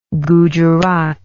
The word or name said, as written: gujarat